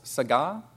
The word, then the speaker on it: saga: Instead of gadol, it's saga, that's spelled S-A-G-A, like saga.